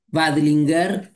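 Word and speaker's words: Veidlinger: Pues un mapa que se movió mucho a lo largo de la historia, pero este libro, ahora sí, del profesor Jeffrey Badingler, se escribe B corta EIDLINGER, Badlinger, Jeffrey.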